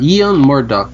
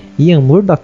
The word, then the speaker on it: Ian Murdock: Olha, eu diria uh, Ian Murdoch.